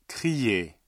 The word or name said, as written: crier